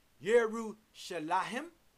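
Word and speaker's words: Yerushalayim (ירושלים): The word Jerusalem, pronounced Yerushalayim in the African Hebrew language, found under the number 3389 on the Hebrew side of your Strong's Hebrew Greek Dictionary, is defined as teaching of peace.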